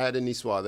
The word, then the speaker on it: niçoise: It's a French word. – Niçoise? – Yes.